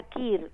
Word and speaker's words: queer: ¿Cómo se escribe care? Q-U-E-E-R. Uh-huh. Uh-huh. Sí, sería Kir, ¿no? – Sí, sí, generalmente se pronuncia así, pero sí.